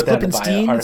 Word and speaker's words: Klippenstein: So let's I believe let's it's Klippenstein it's pronounced.